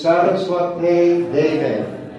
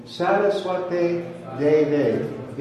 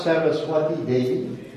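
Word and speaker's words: Sarasvate Deve: So here, then Prabhupada gave us this pranam mantra for him, and his name in that pranam mantra is Saraswate Deve. With a long A, we don't have diacritics in this presentation, but Saraswate Deve, It's not Saraswati Devi, as I sometimes hear people sing.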